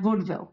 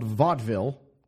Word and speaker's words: vaudeville: Whereas you have vaudeville. – Yeah, vaudeville. – We're vaudeville, three syllables.